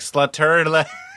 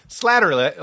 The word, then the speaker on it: Slattery: I was always like, Slutterly. And he was like, wait. – Slattery, like flattery, slattery.